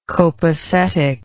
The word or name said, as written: copesetic